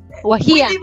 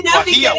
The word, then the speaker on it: Guajía: ¿Así se dice o no? ¿qué? ¿Cuál es el resto? tenki por estar con Wagia. – Guajía. – Guajía, ¿eh? – Por estar con Wagia.